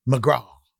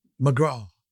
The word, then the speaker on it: McGrath: McGraw. That was his name, McGraw. Boy, he was great. By the way, that's spelled McGrath.